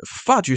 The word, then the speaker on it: fudge: 这个 D 是没有发音的，好，那个 G 是没有发音的。 fudge 是什么嘞？fudge 如果你查那个剑桥字典的话，中文解释叫做奶油软糖。